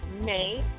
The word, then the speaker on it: Mae: My name is author Sharon May. May is spelled M-A-E King.